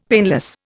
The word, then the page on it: painless: Indian